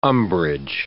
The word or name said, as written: umbrage